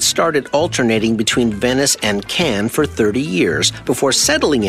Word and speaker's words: Cannes: Even though it is spelled C-A-N-N-E-S, it is pronounced Cannes, not Cannes or Cons.